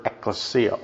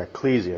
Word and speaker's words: ecclesia: In the New Testament, in the first century, scholarship has shown us that the word ecclesia, or ecclesia, if you want to pronounce it that way, meant an assembly, a group of people assembling together.